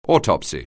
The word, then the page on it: autopsy: anglais